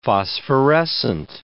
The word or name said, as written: phosphorescent